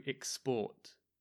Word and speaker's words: export: So as a noun, it's an export. As a verb, it's to export. So maybe you can hear the stress with the verb is in the second syllable.